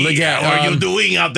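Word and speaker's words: Legette: Did I pronounce the name wrong? – Leggett. – Yeah, okay, Xavier. Close enough. I went with the French version.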